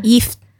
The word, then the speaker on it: gift: Se dice gift. Y se escribe como gift, uh-huh, como regalo, sí, en inglés.